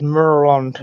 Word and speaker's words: Maryland: that we locals pronounce as Maryland, not the one in Lagos.